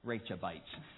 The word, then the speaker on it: Rechabites: Whenever I say it, I'm going to think my wife said it's like Rachel, all right? Rechabites.